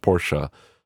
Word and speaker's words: Porsche: Because it's pronounced Porsche, being one of them.